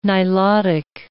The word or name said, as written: Nilotic